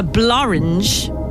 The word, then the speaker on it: Blorenge: A rare example of a word that rhymes with orange. The blorange. Blorange. And I'll spell it for you. B-L-O-R-E-N-G-E.